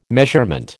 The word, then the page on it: measurement: American English